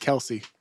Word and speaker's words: Kelce: It's pronounced Kelce, by the way.